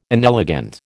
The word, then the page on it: inelegant: American English